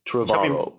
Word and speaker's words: Trevorrow: Und ja, ich habe nachgeschaut, das ist, wie sein Nachname ausgesprochen wird. Trevorrow.